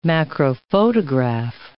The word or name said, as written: macrophotograph